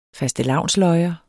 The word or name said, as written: fastelavnsløjer